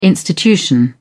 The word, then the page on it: institution: British English